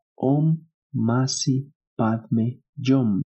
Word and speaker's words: Om Mani Padme Hum: Se escribe Om Mani Padme Hum, pero se pronuncia como Om Masi Padme Yom.